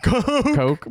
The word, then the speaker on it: Koch: How do you say their name? – Coke. Oh my God.